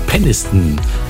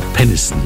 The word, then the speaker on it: Peniston: Schöner Sommerhit aus dem Jahr 1991 hier bei uns in Deutschland von CC Peniston mit Finally. Muss man schnell aussprechen den Namen Peniston, sonst klingt's doof, ja, sonst heißt sie ja Peniston.